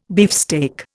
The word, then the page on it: beefsteak: Indian